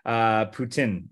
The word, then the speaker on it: poutine: And that's a traditional poutine is the way it's pronounced here, but most people just say poutine.